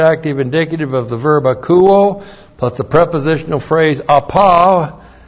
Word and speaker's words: akouo: And then we have the verb akouo. A-K-O-U long O. Akouo.